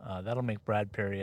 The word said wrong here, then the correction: Brad Berry: at North Dakota happy. Brad Berry, I should say.